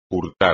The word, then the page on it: hurtar: espagnol